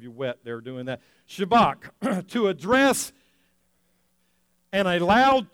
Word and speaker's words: Shabbat: To say that right, you got to say it kind of like you got a kernel of corn stuck in the back of your throat. Shabbat.